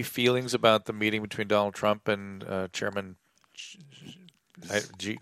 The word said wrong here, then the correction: Xi Jinping: Getting it right? G? Chairman G? Is that how you pronounce his name? – X-U. – X-I. – What's the name? – Xi. – Xi Jinping. – Xi Jinping, yeah.